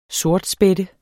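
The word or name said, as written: sortspætte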